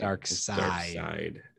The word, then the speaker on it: Darkseid: Darkseid.